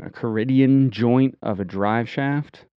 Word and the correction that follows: Cardan: No, Cardan.